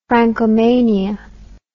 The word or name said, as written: francomania